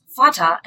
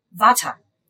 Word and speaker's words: Vater: So father would be pronounced as Vater and not Vata.